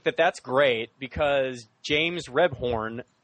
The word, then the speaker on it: Rebhorn: First of all, I love the use of, for the life of me, I don't know how to pronounce this actor's name, but it is James Rebhorn. Is that how you say it? Was it Rebhorn? – Or? I think it's Rebhorn.